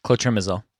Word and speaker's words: clotrimazole: Clotrimazole. I think I'm pronouncing this differently every time, but... – Yeah, clotrimazole.